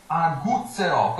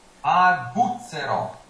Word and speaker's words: aguzzerò: Questo è completamente diverso, è una G abbastanza dura. Aguzzerò. – So that's a hard G because it's not followed by an iron E, it's followed by a U. – No, an U, so gu. È come la parola Gustav.